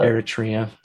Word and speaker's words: Eritrea: He was sent to Sudan in an attempt to liberate Ethiopia from the Italians, and was injured for a third time in, how do we pronounce this country's name? – I think it's Eritrea, but Eritrea.